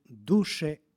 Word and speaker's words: duše: Tako da vokativ od imenice duh glasi duše sa kratkim akcentom, dok vokativ imenice duša glasi blagoslovi duše moja gospoda sa dugosilaznim akcentom.